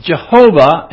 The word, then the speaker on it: Jehovah: And some pronounce it Jehovah, and others pronounce it Yahweh, and they're putting different vowel points in there.